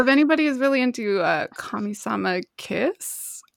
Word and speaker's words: Kamisama Kiss: Yeah, it's Kamisama Kiss. And I think it's like, in like the Japanese is Kamisama Hajimashita.